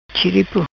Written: Chiripo

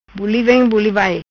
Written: Buli Vem Buli Vai

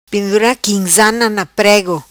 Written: Pendura Quimzana Na Prego

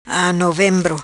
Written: Novembro

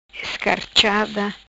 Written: Escarchada

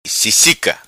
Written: Sisica

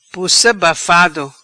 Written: Pussa Bafado

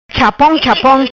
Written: Chapom Chapom

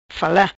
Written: fala

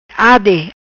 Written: Ade

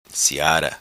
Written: Siara

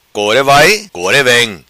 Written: vai core vem

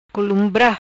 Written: Columbra

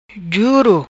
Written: Juro

